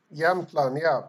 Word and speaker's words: Jämtland: Jämtland. – Jämtland, so Jämtland will be south of Abisko, but still belongs to the Nordic region of Sweden.